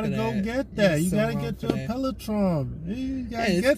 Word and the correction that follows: Peloton: Still saying it wrong. – Hey. But yeah, you know. – So wrong. I can't with you. – Yeah, I get you. Gotta work them hips. Gotta work. It's the way it's spelled. – Peloton. – Peloton? – Yes.